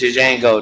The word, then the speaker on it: Django: How could you have a silent letter before he says my name is Django. The D is silent. – Django, yeah.